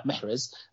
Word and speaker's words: Mahrez: So I have Mahrez, or I'm going to say his name right, Mahrez, just like I did with Benrahma.